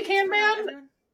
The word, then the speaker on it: Kanban: How did you say that? – I say Kanban. Yeah. – Kanban. I've heard it both ways.